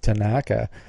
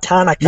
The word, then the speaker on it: Tanaka: I noticed that too, like Tanaka. – Tanaka. – The emphasis was on the wrong syllable.